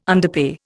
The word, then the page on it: underpay: Indian